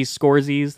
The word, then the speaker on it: Scorsese: It is Marty Scorsese. That's how you pronounce it.